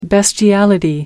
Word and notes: bestiality: American English

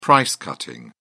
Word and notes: price cutting: British English

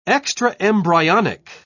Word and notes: extraembryonic: British English

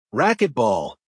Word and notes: racquet ball: American English

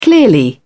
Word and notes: clearly: British English

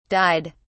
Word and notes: dyed: British English

